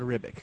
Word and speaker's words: Ribic: Ribic? Am I pronouncing that right? – Esad Ribic.